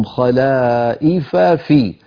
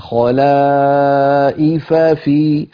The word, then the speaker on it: خلائف في: الآية الأخيرة التي قرأناها، ثم جعلناكم خلائف في التقى هنا في النطق فاءان، خلائف آخرها فاء، في أولها فاء، فالفم من عادته إذا جاء متماثلان يعني أحيانا يسرع قليلا أو يخفي أحد الحرفين، فهنا لابد من البيان، نقول خلائف في، خلائف في، فنبين كلا الفاءين.